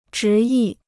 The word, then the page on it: 执意: zhí yì